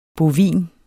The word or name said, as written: bovin